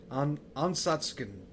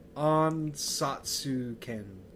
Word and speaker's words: Ansatsuken: It's Ansutsuken. Ansatsuken. Yeah, it's hard to pronounce. Okay. Yes. There you go. – You said it was a mouthful. – Yeah. – I guess it is. – It's like, more phonetically, it's Ansatsuken.